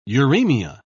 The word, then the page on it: uraemia: British English